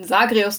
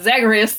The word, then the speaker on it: Zagreus: Ja, Hades hat den Thron leer zurückgelassen und daraus ist ein Machtkampf entstanden zwischen den Königskindern Melinor und Sagreus, die sich, muss mich dran gewöhnen, Sagreus zu sagen und nicht ihn immer Zagreus zu nennen, wie man das so in der englischen Aussprache kennt.